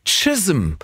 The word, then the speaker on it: Chisholm: Melanie Jane Chisholm. So wird es ausgesprochen. Das ist aber wirklich ein C, was da geschrieben steht und wird mit T ausgesprochen.